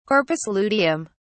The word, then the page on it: corpus luteum: British English